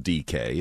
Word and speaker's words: Dike: It's not spelled DK, it's spelled D-I-K-E, but it's DK on that long touchdown, pylon route there, because he was chasing.